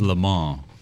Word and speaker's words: Le Mans: I think it's Le Mans. I think the S is silent. – Le Mans.